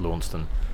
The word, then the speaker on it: Launceston: I think it's Launceston. I've been telling everyone it's Launceston.